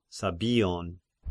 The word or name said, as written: Sabbion